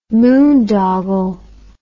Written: moondoggle

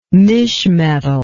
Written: mischmetal

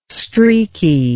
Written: streaky

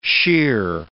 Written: sheer

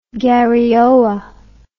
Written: garioa

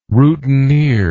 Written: routineer

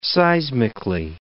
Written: seismically

